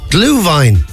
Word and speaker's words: Glühwein: Gulwain, is it that drink that people have? – Glühwein. It's hot wine, Glühwein, yeah. – Glühwein, is that how you pronounce it? – Yeah, Glühwein.